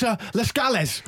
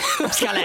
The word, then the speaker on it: Lascelles: Chris Camaro got his pronunciation of his name so wrong, I literally had no idea who he's talking about. – And it's Lascelles. – Lascelles.